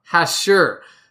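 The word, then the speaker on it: hachure: The next word is hashur. No, hashur. Emphasis on the second syllable.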